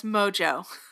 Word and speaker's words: Mongeau: That's Mojo. – What did I say? – Mongeau? – That's you spell. – I know. – Mojo? It's spelled Mongeau. – She goes by Tana Mojo.